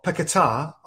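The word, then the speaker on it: Paquetá: But Lucas Paqueta or Paqueta, I think is actually the correct pronunciation, but I'm sure it's going to get butchered for a few weeks from all over the place.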